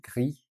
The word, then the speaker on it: gris: No S at the end, gris.